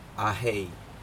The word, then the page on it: ahei: Aneityum